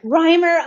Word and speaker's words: Reimer: Is that how I say your last name? – Reimer.